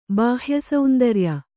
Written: ಬಾಹ್ಯಸೌಂದರ್ಯ

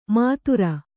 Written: ಮಾತುರ

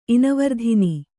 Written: ಇನವರ್ಧಿನಿ

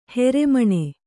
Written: ಹೊರಮುನಿ

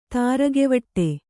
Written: ತಾರಗೆವಟ್ಟೆ

